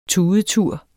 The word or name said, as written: tudetur